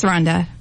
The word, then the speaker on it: Randa: Well, Cassie is the marketing manager, eBay Seller Marketing, and Randa, is it Randa or Rhonda? – It's Rhonda. – Rhonda? – But it's spelled like Randa.